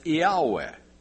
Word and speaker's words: Yahweh: They were well aware of this term in the Hebrew Old Testament, and they wrote it in Greek as I-A-O-U-E, and if you pronounce it, it's Yahweh.